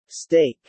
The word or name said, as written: steak